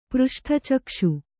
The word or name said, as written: ಪೃಷ್ಠಚಕ್ಷು